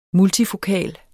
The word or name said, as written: multifokal